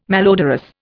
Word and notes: malodorous: Indian

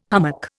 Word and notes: hummock: Indian

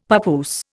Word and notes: papoose: Indian

